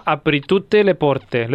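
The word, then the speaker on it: Apri tutte le porte: que cantaba Apritute teleporte ¿Lo he dicho bien esto? – sí super. sí Sí, le porte.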